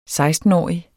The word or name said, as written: sekstenårig